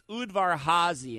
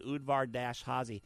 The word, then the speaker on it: Udvar-Hazy: So it's Udvar-Hazy, in case you want to learn how to pronounce it, Udvar-Hazy.